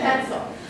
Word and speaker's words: quetzal: A quetzal? Did we say that right, Hannah? – Yeah. – Okay, a quetzal.